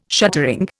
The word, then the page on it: shuttering: Indian